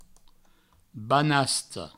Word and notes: banasta: banasto; Provençal

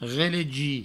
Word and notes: relegir: relegi

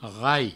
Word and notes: rai: provençal

